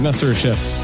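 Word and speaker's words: MasterChef: Il sera avec nous autour de 16h10 pour venir nous parler de son expérience à Master Chief Québec, Master Chef. J'aurais pas de le prononcer en anglais, on dirait que mais c'est Master Chef.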